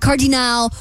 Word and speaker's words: cardinal: How did you pronounce cardinal? – Cardinal! – It's like, and then I heard priest, and I'm like, did she just say cardinal? – That's how they say it in France. – My bad. – Cardinal. – Cardinal. Yeah. Like croissant.